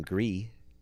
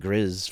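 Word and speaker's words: Gris: Which, speaking of mispronouncing game names, me and mom started playing Gree. – Hmm? – And I've been saying Grizz for years, or Grees, not knowing it was French for gray.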